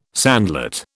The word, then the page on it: sandlot: British English